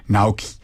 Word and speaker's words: Naoki: And she was playing, ella estaba actuando, y un amigo suyo, un ingeniero amigo de Naoki, pues estaba, tenía, Nakoi, perdón, estoy pronunciándolo mal, Naoki.